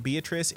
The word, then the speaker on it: Beatrice: It is spelled like Beatrice. It is pronounced Beatrice.